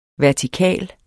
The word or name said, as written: vertikal